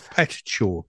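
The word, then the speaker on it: Petrichor: it's Petichor because it's spelled P E T I C.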